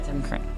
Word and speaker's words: Kring: Yeah, Tim Kring. – Mm-hmm. – So I was saying the name right. – Right. K R I N G.